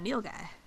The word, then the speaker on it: nilgai: N-I-L-G-A-I. – N-I-L-G-A-I. – It's that Neil guy.